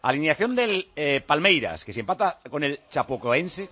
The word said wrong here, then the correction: Chapecoense: ¿cómo es? ¿Cómo se llama? A ver si lo digo bien. Chapecoense.